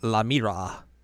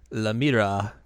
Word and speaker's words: La Mira: It's La Mira. Because it's basically La Mira, which is the mirror in French. – But with La, because she's a girl.